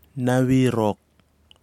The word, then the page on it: nawirok: Kwamera